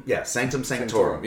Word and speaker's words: Sanctum Sanctorum: They walk up to the Sanctum Sanctorum. I believe I pronounced that correct. – Sanctum Sanctorum.